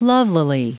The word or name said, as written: lovelily